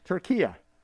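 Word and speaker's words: Türkiye: And finally, from Forbes, the United Nations accepted a formal request by the country Turkey to rebrand as Turquia. That's spelled T-U, with an umlaut over the U, R-K-I-Y-E.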